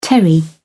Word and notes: terry: British English

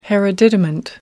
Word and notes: hereditament: American English

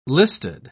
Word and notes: listed: British English

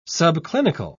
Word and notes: subclinical: British English

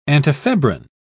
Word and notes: antifebrine: British English

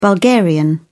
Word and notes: bulgarian: British English

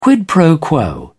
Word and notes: quid pro quo: British English

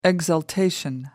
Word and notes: exaltation: American English

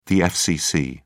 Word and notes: fcc: British English

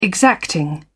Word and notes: exacting: British English

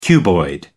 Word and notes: cuboid: American English

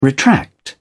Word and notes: retract: British English

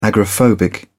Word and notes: agoraphobic: British English

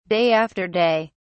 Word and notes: day after day: British English

